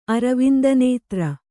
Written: ಅರವಿಂದನೇತ್ರ

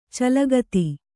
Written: ಚಲಗತಿ

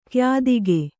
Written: ಕ್ಯಾದಿಗೆ

